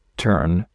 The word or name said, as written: tern